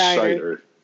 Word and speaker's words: Scheider: Are you calling him Roy Scheider or Roy Scheider? – Clearly I'm pronouncing it wrong. I call him Roy S. Is it not Scheider? – It's Scheider.